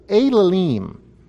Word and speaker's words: Elalim: But then it rhymes with the word for idols, and in Hebrew the word for idols is Elalim.